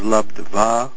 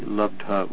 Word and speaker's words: labdha: There's a Sanskrit mistake, interestingly enough, if you want to note that, where it says the first word is labdva. It should be labdha with a short A instead of a V and a long A. Labdha.